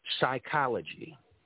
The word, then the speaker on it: psychology: P is also used as a silent letter. Psychology.